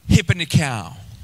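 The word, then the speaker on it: hypernikao (ὑπερνικάω): And this Greek word is pronounced something like hypernikao. Everyone say hypernikao.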